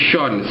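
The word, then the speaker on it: Sion: For information, my name is Sean, spelled like Sierra India Oscar November. Sean is my name, and I'm very glad to be the first station from Malaysia to work you.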